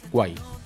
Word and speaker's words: Kwai: Hay una red social que se llama Quai. ¿No? – Guay. No sabría confirmarlo. – Se escribe K-W-A-I. Guay.